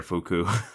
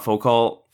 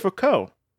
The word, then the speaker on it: Foucault: You don't pronounce most of it, but it's Foucault.